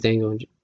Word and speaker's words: Django: That or I really like Django Unchained. The D is silent.